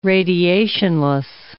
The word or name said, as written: radiationless